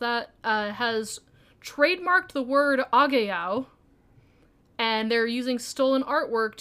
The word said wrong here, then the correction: Ahegao: Oh, Ahegao? – Yeah.